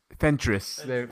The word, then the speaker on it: Fentress: Fentress, from The Athletic.